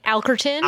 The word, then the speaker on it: Alkerton: I would say like Alkerton. – Alkerton. – But like you use the L. Sometimes people just throw out Alkerton.